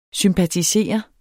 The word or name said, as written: sympatisere